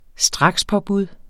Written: strakspåbud

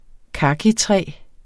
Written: kakitræ

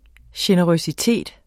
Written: generøsitet

